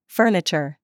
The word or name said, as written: CHURCH